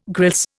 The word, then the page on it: grilse: Indian